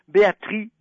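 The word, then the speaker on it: Béatrix: Si on parle de porte-bonheur, je vais vous dire, il y a une femme merveilleuse qui s'appelle Béatrice Beck. Ça s'écrit avec un X et le X ne se prononce pas.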